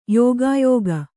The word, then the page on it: ಯುಗಯೋಗ: yuga yōga